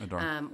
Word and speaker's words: Adar: Is that pronounced right? Or Adar? – Mm-hmm. Adar.